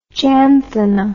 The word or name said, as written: janthina